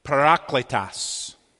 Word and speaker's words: Parakletos: Real Greek people, and I found this pronunciation on it to it, I think would pronounce it, Parakletos.